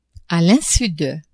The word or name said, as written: a l'insu de